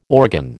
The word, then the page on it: organ: American English